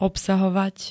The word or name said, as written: obsahovať